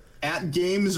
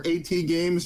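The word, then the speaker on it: AtGames: At Games or AT Games, however you want to call it. Yeah. I call it At Games.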